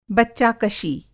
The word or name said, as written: बच्चाकशी